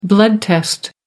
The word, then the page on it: blood test: American English